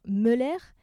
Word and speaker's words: Möller: En 2010, lors des JO de Vancouver, le lugeur allemand David Moller, alors je prononce en français mais il y a un tréma donc on devrait dire Möller, mais en français ça donne molaire, est devenu un aptonyme en français donc parce qu'il a mordu dans sa médaille d'argent et s'est cassé une dent.